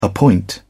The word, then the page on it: appoint: British English